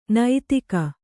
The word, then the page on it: ನೈತಿಕ: naitika